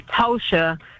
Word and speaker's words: Tosh: Well, my name is spelled with an O, which is unusual, L-A-T-O-S-H-A. So people want to call me Tosha, so I have to tell them it's Tosh like Josh.